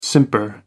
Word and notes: simper: American English

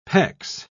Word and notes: pecs: British English

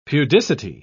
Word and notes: pudicity: British English

